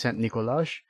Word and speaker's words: Nikolaj: And he's also Nikolai, I think is how you pronounce it. It's spelled with a J at the end, but so I still think that's Nikolai. He's Nikolai Bort. – I have heard the name Nikolaj, yes. – Okay, it might be Nikolaj. Nikolaj Boyle in Brooklyn Nine-Nine. – Oh, yes, if it's Brooklyn Nine-Nine, it is 100% Nikolaj.